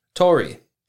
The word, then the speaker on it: tori: And that is Tori. Tori is spelt T-O-R-I.